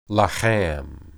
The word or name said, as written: laham